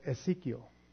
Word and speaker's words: Exiquio: But it's Ezekiel. And it's spelled E-X-I-Q-U-I-O.